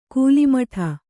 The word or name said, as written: ಕೂಲಿಮಠ